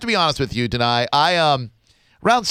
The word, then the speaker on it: Danai: Did I get that right, Danai? – Danai. – Of course, Danai.